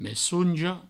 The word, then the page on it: messonja: messouonjo